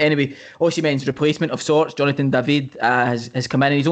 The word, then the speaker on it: Jonathan David: They have signed Jonathan David, to pronounce it in the English way, but he's French-speaking Canadian, so technically should be pronounced Jonathan David.